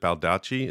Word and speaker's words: Baldacci: Yeah, I'm just about finished Memory Man by David Baldacci. Is that how you say his name? – Oh, yes. I've read a few of his books.